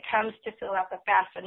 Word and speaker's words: FAFSA: And before we went to break, you know, you had mentioned FAFSA, which is the F-A-F-S-A, pronounced FAFSA, which I just learned today.